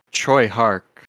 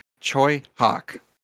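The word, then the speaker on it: Tsui Hark: So his name is pronounced Choi Hark. Choi Hawk.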